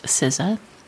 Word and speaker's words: SZA: I'm obsessed with the latest album from the performing artist SZA. S-Z-A is her performing name.